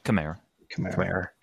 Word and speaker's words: Kamara: Is it Kamara or Kamara? – Kamara. – I was going to say, I feel like I'm saying that wrong.